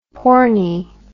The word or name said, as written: pornie